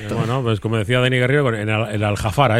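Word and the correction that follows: Al-Gharafa: este, al... – La exigencia. La Gavarra, el Algarrafa. – Algarrafa, ese. Algarrafa, que se equivocó de pronunciación.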